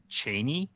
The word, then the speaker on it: Chani: Like, there are ways that Frank Herbert pronounces, like, he calls Chani Chaney, which I'm glad they didn't go with.